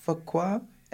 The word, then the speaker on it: Fuqua: Nicole Murphy, and that's it, and Antoine, I think you pronounce it Fuqua? – F-U-Q-U-A.